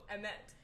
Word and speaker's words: Emmet: Uh, how do you pronounce your name? – Oh, Emmet.